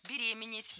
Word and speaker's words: беременеет: Например, если мужчина ценит свою женщину, она беременеет, у него падает тестостерон. – Беременеет. Неверно. Правильный вариант – беременеть, беременеет. Ударение в этом слове ставим всегда на второй слог – беременеть.